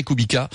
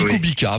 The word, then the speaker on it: Kubica: Et au-delà de ces essais de Hongrie, on s'interroge actuellement beaucoup sur le retour du Polonais Kubica ou Kubica, chacun prononce comme il veut. Bon, moi je dis Kubica, voilà, parce que c'est... – Oui, à la française, Ok, merci.